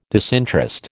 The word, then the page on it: disinterest: American English